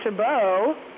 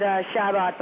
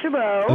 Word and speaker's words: Chabot: It's actually Lake Chabot. – What? Oh, yeah, John, you messed up. – Yeah, you just, you know, a lot of people pronounce it Shabbat, but it actually is pronounced Lake Chabot.